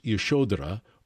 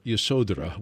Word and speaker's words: Yashodhara: Her name, by the way, is, it can be pronounced Yashodhara or Yasodhara. Which one do you prefer? – I usually just pronounce it Yasodhara.